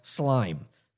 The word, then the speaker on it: SLM: And most importantly, I learned SLM, pronounced slime, the source code tracking tool, which is like GitHub, which came much later.